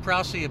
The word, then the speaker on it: Prause: So yes, it is Prousey. P-R-A-U-S-E, but pronounced Prousey.